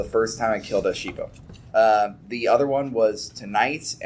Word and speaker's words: Xipil: Also, their name is, their name is Sheeple, but it's, like, spelled with an X. – X-I-P-I-L.